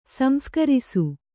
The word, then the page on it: ಸಂಸ್ಕಾರಿಸು: samskārisu